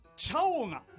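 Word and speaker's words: チャオガ: これあのアクセント間違っていたら教えてくださいね。タイトルチャオが、チャオが、はい、合ってます。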